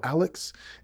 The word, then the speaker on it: Alyxx: This War by the band Alex. And just to let you know, that is spelled A-L-Y-X-X.